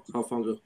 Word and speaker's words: Hufanga: Yeah, Hufanga.